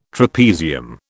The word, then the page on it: trapezium: British English